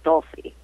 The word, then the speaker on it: Dulcie: Actually, a lot of people have had trouble with that, but it's pronounced Dulcie. – Dulcie. I'm thinking in Italian, I guess the C followed by a vowel is a ch sound, so I was confused.